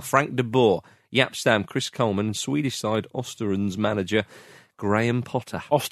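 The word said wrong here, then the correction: Östersund: Ostersons. And you're a host of On the Continent, so you knew the correct way to pronounce that.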